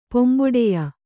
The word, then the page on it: ಪೊಂಬೊಡೆಯ: Kannada